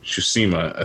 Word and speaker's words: Tsushima: But I really like watching, in this game, Ghost of Tsushima.